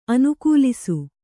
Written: ಅನುಕಲಿಸು

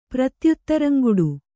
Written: ಪ್ರತ್ಯುತ್ತರಂಗುಡು

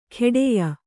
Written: ಖೇಡೆಯ

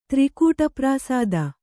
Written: ತ್ರಿಕೂಟಪ್ರಾಸಾದ

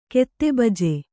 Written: ಕೆತ್ತೆಬಜೆ

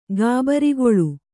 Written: ಗಾಬರಿಗೊಳು

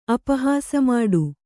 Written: ಅಪಹಾಸಮಾಡು

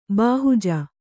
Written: ಬಾಹುಜ

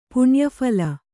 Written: ಪುಣ್ಯಫಲ

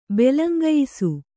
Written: ಬೆಲಂಗಯಿಸು

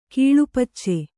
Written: ಕೀಳುಪಚ್ಚೆ

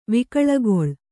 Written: ವಿಕಳಗೊಳ್

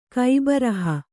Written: ಕೈಬರಹ

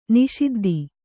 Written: ನಿಷಿದ್ಧ

